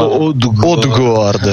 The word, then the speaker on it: Odgaard: Odgaard sarebbe la pronuncia corretta, poi ovvio che viene più facile dire Odgaard.